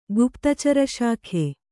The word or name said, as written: ಗುಪ್ತಚಾರಶಾಖೆ